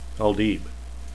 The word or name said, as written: aldieb